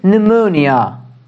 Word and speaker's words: pneumonia: The next word is pneumonia. Pneumonia. P. Pneumonia. Pneumonia. Okay, we don't pronounce P. Okay, we just say pneumonia.